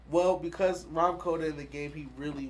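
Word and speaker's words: Rahm Kota: How do you say his name? – Rom Koda.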